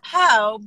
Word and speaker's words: Po: It's Poe because people can't say my real name, which is Paola.